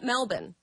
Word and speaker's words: Melbourne: Like, just unbelievable that they tried to pull off a game after just landing in, I'm going to call it Melbourne. Because that's how they say it there.